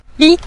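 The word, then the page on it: mi: tone 5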